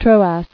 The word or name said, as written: Troad